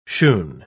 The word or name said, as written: shoon